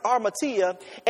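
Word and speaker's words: armatia (ἁμαρτία): Sin is defined in the Greek as is pronounced armatia, and it means to miss the mark.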